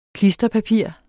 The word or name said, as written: klisterpapir